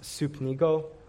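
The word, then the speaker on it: supnigo (συμπνίγω): The original Greek word that was used for this, pronounced supnigo, which literally means to choke utterly.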